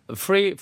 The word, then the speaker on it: free: Yeah, F-R-E-E, free. – F-R-E-E, F-R처럼 안 들리고 R로 들리죠. F-R-E-E. 네. – 하고 그 숫자 3랑 좀 다르죠. 3가 TH니까 th 사운드 조금 들어가고 숫자는 이 공짜는 free.